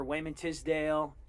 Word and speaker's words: Wayman Tisdale: You were producing that music for Wayman Tisdale, and did I say it right? – Yeah.